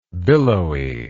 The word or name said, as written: billowiness